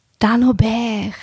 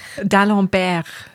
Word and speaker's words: Dalembert: Wir haben wieder ein neues Buch, nämlich «Die blaue Mauer» von Louis-Philippe D'Alembert. Warte, ah, ihr es gibt D'Alembert. D'Alembert. D'Alembert.